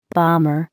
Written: bomber

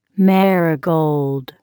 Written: marigold

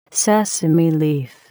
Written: sesame leaf

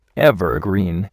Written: evergreen

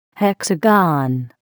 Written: hexagon

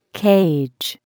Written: cage

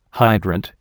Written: hydrant

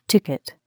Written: ticket

